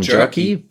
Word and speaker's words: Jerky: zu dem Thema veganes, äh, wie wird es ausgesprochen? – Jerky. Jerky.